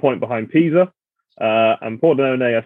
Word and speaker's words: Pordenone: and I am taking a home win for Brescia against, the pronunciation is going to be horrible, Pordenone? – Yeah, that's right.